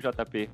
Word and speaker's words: JP: Eu estou aqui com meu amigo JP Melo, que é, é, é assessor de imprensa, é isso, JP? Falei certo? – Isso, é exato.